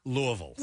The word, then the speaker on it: Louisville: Louisville. The Ville is pronounced Vull.